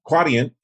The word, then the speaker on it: Quadient: So you are now with Quadient. Did I pronounce that right? – Yes, Quadient. Yes. – Quadient. And tell me a little bit about the company and how you chose to be there.